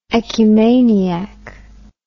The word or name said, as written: ecumaniac